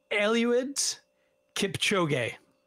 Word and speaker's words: Eliud Kipchoge: I believe it's pronounced Eliud Kipchoge.